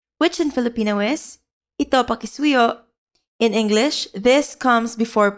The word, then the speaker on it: pakisuyo: In Filipino, please is pakisuyo. Pakisuyo. Let's break it down by syllable. Pa-ki-su-yo. Now let's hear it once again. Pakisuyo.